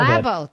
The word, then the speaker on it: Labo: Joining us now, Dr. Daniel Labau. Doctor, am I mispronouncing your name? Is it Labau? Labau? How do you pronounce your name? – Actually, Labo, if you want to be formal. – Labo, that is how I'd like to do it the right way.